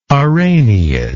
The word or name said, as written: araneid